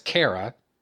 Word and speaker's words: Kara: I mean, first off, Supergirl, which is to say Kara, and that's her fucking name, by the way. I'm not going to follow the stupid-ass TV show pronunciation of her name. Her name is Kara.